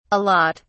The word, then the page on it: a lot: British English